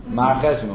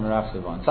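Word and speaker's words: Marcheshvan: Mar Cheshvan, or more properly pronounced, Merach Sivan.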